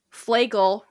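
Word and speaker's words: Flegel: Flagel rhymes with bagel.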